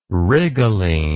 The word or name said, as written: rhigolene